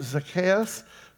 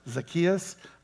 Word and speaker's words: Zacchaeus: There was a man there named Zacchaeus. That's the actual proper pronunciation of it. You'll hear it all the time pronounced Zacchaeus.